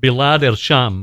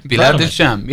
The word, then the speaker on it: Bilad al-Sham: And can you also talk a little bit about, I hope I don't mess up the pronunciation, the Bilad el-Sham movement? – That's actually correct. – Thank you. – All right. Bilad al-Sham.